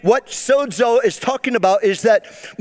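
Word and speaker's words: sozo (σῴζω): And the Greek word being saved is the word, I'll spell it to you first, S-O-Z-O. F-O-Z-O. This is a Greek word. And it's pronounced sozo. Sozo.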